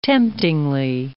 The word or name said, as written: temptingly